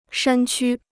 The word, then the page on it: 山区: shān qū